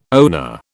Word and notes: owner: British English

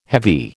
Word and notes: heavy: American English

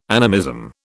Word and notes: animism: British English

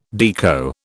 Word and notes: Deco: British English

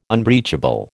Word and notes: unbreachable: American English

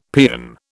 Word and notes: paean: British English

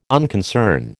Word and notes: unconcern: American English

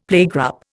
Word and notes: playgroup: Indian

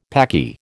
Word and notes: Paki: American English

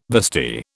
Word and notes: vestee: British English

